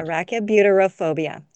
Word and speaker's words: arachibutyrophobia: Arachibutyrophobia. – That's a lot. – Arachibutyrophobia.